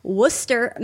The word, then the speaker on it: Worcester: Robin Joyce writes, Fourth Wall Stage Company is a nonprofit community theater group in Worcester, Massachusetts, and they wrote how to pronounce that for me because I'm a dumb Californian.